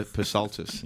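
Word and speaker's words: Psaltis: So just on that, somebody asked him about his surname, Peter Pasaltis. – Yes. – And he said, the P is silent.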